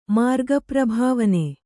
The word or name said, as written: ಮಾರ್ಗಪ್ರಭಾವನೆ